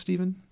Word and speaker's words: Stefan: Spelled Stefan, pronounced Steven.